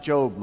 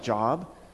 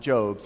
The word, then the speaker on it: Job: And she goes, "I think it's pronounced Job." I said, "Well, it's spelt like Job." "Yeah, but it's pronounced Job."